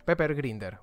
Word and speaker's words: Pepper Grinder: Pepe Grinder. Sí, sí, se llama así. Pepper. Pepper Grinder, ¿vale? Va básicamente mejor pronunciado.